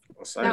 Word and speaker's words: Osiris: It's Osirius. Is that how you say it? – Osiris. That one I'm not sure. – Osiris, Set, Isis, and Horus. – Oh, Osiris. Osiris.